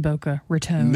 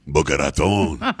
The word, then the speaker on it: Boca Raton: I'm not really sure, because, like you said, you never lived in Boca Raton.